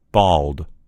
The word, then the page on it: bald: American English